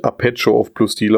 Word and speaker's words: Arpeggio of Blue Steel: Das Ganze spricht man nämlich eigentlich Arpeggio of Blue Steel aus.